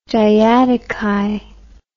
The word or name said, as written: diadochi